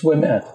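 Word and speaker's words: Ouimet: It's Wimet. – Wimet. – Manon Wimet. Just because you've been to France or Quebec on your bicycle. – Manon, don't want to pronounce the N. Wimet. Manon. – She's not going to like any more for saying that five times, you know.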